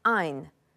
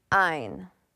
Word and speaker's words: Ayn: It is pronounced Ayn, as if with long I. Ayn.